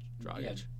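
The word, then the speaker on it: Dragić: Dragic? – Yeah. Dragic.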